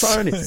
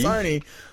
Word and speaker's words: Sony: I think it's pronounced Sony. – Sonny. – Nah, man. – Sonny. I mean, that's another one my dad refuses to pronounce correctly. Sonny. – Sonny. – It's a Sonny. They're pretty good. – Yeah. I haven't heard of Sonny, Dad. I've heard of Sony. – Sony. Like they pronounce it. – like they actually say themselves.